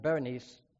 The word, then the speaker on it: Berenice: Ptolemy marries his daughter Bernice, or actually it's pronounced Berenice, to Sirius, King Antiochus II, Theos, after Antiochus divorced his wife Leotis.